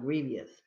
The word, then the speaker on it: grievous: I think the E is silent, grievous.